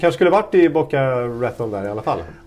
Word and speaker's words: Raton: Raton. Tänk på batong. – Ja. Batong. Just det, Boca batong. Mm.